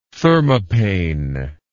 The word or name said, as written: thermopane